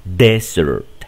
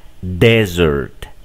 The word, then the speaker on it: desert: Aquí, en este caso, desierto solamente lleva una S y se va a pronunciar el acento en la primera sílaba. Aquí decimos desert, desert, desert.